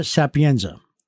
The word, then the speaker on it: Sapienza: So today our guest is the Yonkers Police Commissioner, Christopher Sapienza.